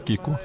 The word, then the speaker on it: Kiko: A princesa Kiko. – Eu fico com medo de pronunciar. – Não, é isso mesmo, é princesa Kiko.